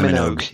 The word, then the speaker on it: Minogue: Yeah, Kylie Minogue. Yes. – Yeah. – Minogue.